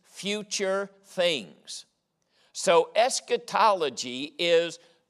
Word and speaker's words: eschatology: It's called eschatology. Now, let me spell that. That's a big old 50-cent word. E-S-C-H-A-T-O-L-O-G-Y. Eschatology.